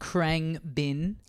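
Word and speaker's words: Khruangbin: but Krang Bin.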